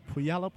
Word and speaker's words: Puyallup: Larson from Puyallup, Washington. If I said it right, as Murdoch goes to the line, and Murdoch sporting full-grown beard. He's a man. He's 40. And he makes the first of his free throws, 29-17. I think you pronounced that town correctly. Isn't that your hometown? You ought to pronounce it correctly.